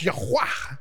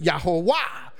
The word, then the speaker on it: Yahweh: And so if you pronounce the vowels, it is pronounced Yahweh, which we pronounce it Yahweh. Some have pronounced it Yahuwah.